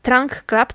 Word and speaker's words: Trunk Club: I tak, pierwszy to jest pomysł ze Stanów Zjednoczonych, nazywa się Trunk, Trunk Club, Trunk, Trunk Club, tak bym to powiedziała, e, Trunk pisze się przez U i przez K na końcu, Trunk Club.